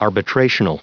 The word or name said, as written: arbitrational